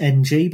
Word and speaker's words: Ng: You look at Perry Ng, which is just NG, but I've looked it up beforehand and it can be pronounced Ng.